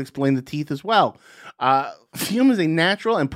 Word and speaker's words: Fume: Fume. That's spelled F-U-M.